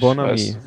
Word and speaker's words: Bonamé: Bonamé, ja. – Wie ist das? – Bonami, glaube ich. – Bonami. – Wird ausgesprochen, genau.